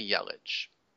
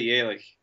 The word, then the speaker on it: Yelich: How do you say it? I say Yelich. – Uh, well, I'm German, so I'd say Yelich.